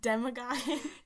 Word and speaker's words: Demogorgon: Demogorgon.